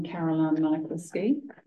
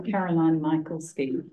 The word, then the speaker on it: Caroline Michalski: I'm Caroline Michalski. – Sorry, Caroline. – Caroline Michalski. Do you want me to spell it? – M-I-C-H-A-L-S-K-I.